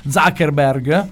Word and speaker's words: Zuckerberg: Dopo questo bellissimo sottofondo musicale Creative Commons, perché sennò questi stronzi ci bloccano le dirette Facebook, maledetto Zuckerberg. Ho detto giusto, sì. Pensavo di aver sbagliato.